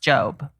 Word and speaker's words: Job: Is it spelled Job? – Yes. – Okay, but it's pronounced Job.